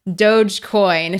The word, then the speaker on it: Dogecoin: You know, I'd go somewhere so that no one tried to kill me for my doggy coin, which I just Googled, and it is pronounced Dogecoin for anyone out there.